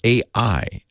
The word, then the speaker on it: AEye: Then there was the guy with his $2,000 Sony camera snapping photos of a self-driving car using technology from a company called AI. I'm sorry. Yeah, AI, but it's not spelled AI. It's spelled A-E-Y-E, like I.